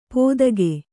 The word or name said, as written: ಪೋದಗೆ